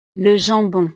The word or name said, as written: le jambon